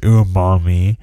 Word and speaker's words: umami: You know, it's pronounced umami. The word is made up of two parts. Umai, which means delicious, and mi, which means taste.